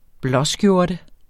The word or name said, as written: blåskjorte